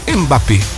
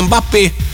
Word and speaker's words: Mbappé: Eu sempre falo o nome dele errado. Mbappé. Tá certo, né? É, Mbappé.